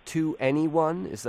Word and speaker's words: 2NE1: I mean, let's start with this 2NE1. Am I saying that right? – Yeah.